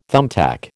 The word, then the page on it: thumbtack: American English